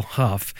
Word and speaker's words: Hough: By the way, it's spelt Hugh, but he says it Hough. – Yeah, my name is David L. Hough, which is H-O-U-G-H, but it's pronounced Hough.